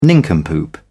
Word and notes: nincompoop: British English

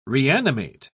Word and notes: reanimate: British English

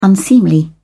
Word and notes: unseemly: British English

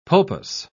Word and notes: pulpous: British English

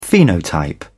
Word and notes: phenotype: British English

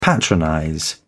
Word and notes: patronize: British English

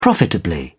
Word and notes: profitably: British English

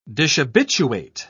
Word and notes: dishabituate: British English

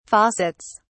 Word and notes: faucets: British English

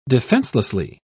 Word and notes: defenselessly: British English

